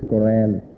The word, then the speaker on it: Quran: the book that is called the Quran or the Quran by Western people's pronunciation, is Quran.